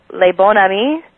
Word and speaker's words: Le Bon Ami: She was honored as Distinguished African American Woman with Vision, Dare, and Care by, you'll have to help me with this pronunciation, Yolanda, Le Bon Ami. – Very good.